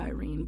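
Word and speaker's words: irene (εἰρήνη): The Greek word for this word is irene. It looks like Irene, but it's pronounced irene.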